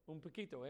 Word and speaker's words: un poquito: Un poquito? Did I say that right? Some of you, is that right? A little. Un poquito, yeah, that's what I thought.